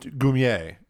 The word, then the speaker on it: Goumier: the Gummer family line actually came from like French immigrants and it was supposed to be pronounced Goumier.